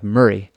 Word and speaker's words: Moray: Who are these guys from the kingdom or earldom of Murray, spelled Moray if you're an American and you've never actually heard it pronounced by a Scot? It's pronounced more like Murray.